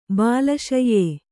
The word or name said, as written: ಬಾಲಶಯ್ಯೆ